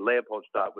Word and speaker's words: Leopoldstadt: i guess the correct pronunciation is a leopoldstadt but Leopoldstadt. Approximating that is acceptable.